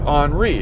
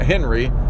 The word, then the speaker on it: Henri: And if it does, again, it would be Henri. It's H-E-N-R-I. It's not Henry. It's Henri, where the H is silent.